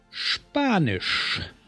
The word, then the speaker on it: Spanisch: Notice the ST and SP are pronounced as sht and sp when they're at the beginning of the word. Hören Sie zu und wiederholen Sie. Spanisch.